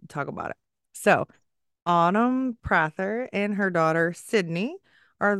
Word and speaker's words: Prather: And I just want to say, because my husband may watch this, it's Prather.